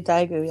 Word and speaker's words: Daegu: On Sunday, there was supposed to be a super concert, SBS super show, something in Daegu. Mm-hmm. Am I saying that right? – I think so, Daegu, yeah.